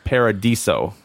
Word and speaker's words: Paradiso: For some reason, I wasn't saying Paradiso. I think I was saying Paradiso. – I've heard other people mispronounce it, so I wouldn't feel bad about it. But yes, there's no I.